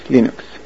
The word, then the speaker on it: Linux: This is Linus Torvalds, and I pronounce Linux, Linux. There you go. Not Linux or any other weird pronunciation, but Linux.